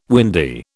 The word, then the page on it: windy: British English